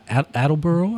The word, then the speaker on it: Attleboro: So, Outside Providence, which is where our guest today is from, Attleboro, I believe.